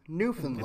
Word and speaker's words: Newfoundland: I wanted to make sure that I pronounced it correctly. I pride myself on pronouncing things correctly whenever possible. And I have I very much have an affinity for Canada. I would love to go to Canada someday. So it is Newfoundland.